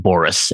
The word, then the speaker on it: Boris: Today it's Hard to Be a God by the Strugatsky brothers, Arkady and Boris.